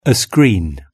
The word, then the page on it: a screen: British English